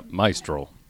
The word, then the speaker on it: Maestral: now roll your R. Yeah, Maestral. Nothing. Maestral. Maestral. Maestral. Oh, she says it the best.